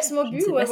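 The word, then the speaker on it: Smoobu: ce Smobu, Smobu, Ouais, Smobu, oui, on peut dire les deux, je crois.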